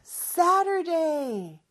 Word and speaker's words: Saturday: 자, Saturday 발음할 때는 저 T 발음을 water가 water가 되는 것처럼 이렇게 조금 혀가 입천장에 살짝 닫아떨어지는 리으라고 비슷한 발음이라고 보시면 돼요.